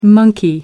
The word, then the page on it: monkey: British English